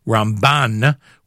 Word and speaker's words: Ramban: Traditionally, we pronounce Rabbi Moshe ben Maimon's initials as Rambam with the emphasis on the first part of the word, and Rabbi Moshe ben Nachman's initials Ramban with the emphasis on the second part of the word, so as to avoid confusing. The 21st day in the month of Teves, the yard site of Shimon ben Yaakov Avinu from last week's Parsha.